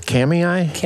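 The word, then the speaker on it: Kamiah: how do you pronounce this, Kamei, Idaho, right along the banks of the Clearwater River. Is that what we got here? – Yep.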